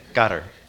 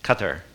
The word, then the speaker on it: Qatar: In Arabic, and I am not fluent in that by any sense of the imagination, but it's more like Qatar. Okay, Qatar. So the tongue goes behind the roof, behind the teeth, your upper teeth, Qatar.